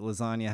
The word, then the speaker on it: lasagna: And the reason it's my favorite Lil Wayne line is because it took me like 3 years to realize that lasagna has a silent G in it.